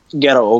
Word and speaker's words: Gadot: Is Gail Gadot? Am I saying that right? – Gadot.